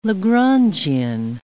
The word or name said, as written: Lagrangian